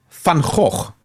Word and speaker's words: Van Gogh: The actual pronunciation of Vincent's surname is Van Gogh, but most people won't be able to pronounce that. Yeah, that's actually how it's pronounced in Dutch, Van Gogh.